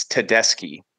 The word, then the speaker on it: Tedeschi: I think your parents need to take me to the Tedeschi Trucks band show. I think I'm saying the wrong. That's hard for me to say. Tedeschi? Am I doing it right? – It's Tedeschi.